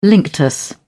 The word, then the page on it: linctus: British English